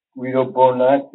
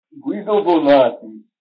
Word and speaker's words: Guido Bonatti: I think I've gotten beyond it now with my interest in Guido Bonatti, is the proper pronunciation of his name. I'm always being criticized for slurring these names and these vowels together. So they should properly be spoken with an Italian accent.